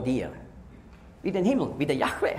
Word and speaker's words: Yahweh: Das bestätigt, dass nicht Jehova die richtige Aussprache ist, sondern Yahweh.